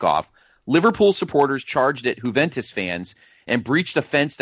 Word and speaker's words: Juventus: Juventus of Italy and Liverpool of England.